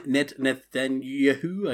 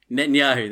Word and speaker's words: Netanyahu: Rolling in to number three is Benjamin Netanyahu. I can't even say his name. – Netanyahu.